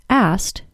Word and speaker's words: asked: Fellow user, Knock, said that he noticed that sometimes it seems like people don't say the K sound in asked, pronouncing it as asked instead.